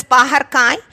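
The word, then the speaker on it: பாகற்காய்: Paharkai.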